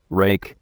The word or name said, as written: rake